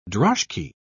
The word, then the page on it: droshky: British English